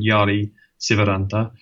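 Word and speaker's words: Jari Syväranta: So we had this lake in mind because one of our collaborators, a guy called Jari Sivaranta, in good Finnish pronunciation, I like it.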